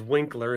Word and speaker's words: Winkler: And I don't like that my last name is Winkler and that rhymes with Stinkler, and that's a that's worse than I got it.